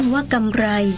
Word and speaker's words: กำไร: สะกดด้วยรอเรือเวลาอ่านต้องออกเสียงรอเรือให้ถูกต้องว่ากำไร